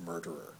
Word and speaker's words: murtherer: That's why you sometimes see murder spelt M-U-R-T-H-E-R. My foul murtherer, but it's not murtherer, it's pronounced murderer.